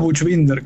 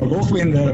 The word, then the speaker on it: Buchbinder: Yo digo Buchbinder, que es como la pronunciación medio castellanizada, sí. Pero Buchbinder, Buch, esa pronunciación alemana está increíble.